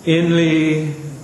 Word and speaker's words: inly: He wants to have the accent on the second syllable. Inly.